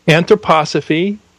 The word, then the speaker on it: anthroposophy: So anthropos, Sophia, anthroposophy. I'm just congratulating you on pronouncing that correctly.